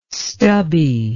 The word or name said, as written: stubby